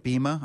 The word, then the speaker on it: Bema: Bema. I think in the Greek it's a long E, so I think it's Bema.